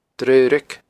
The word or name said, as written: Draoraig